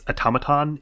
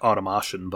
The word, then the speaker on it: automaton: Um, first of all, let me just shout out that guys, it's automaton is the name. Uh, you know, I know Saffron Olive pronounces it like automation, but that, that's just uh, me.